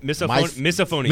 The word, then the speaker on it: misophonia: Misophonia, or yes. I'm sure I'm saying that wrong, but misophonia. – Misophonia. There you go.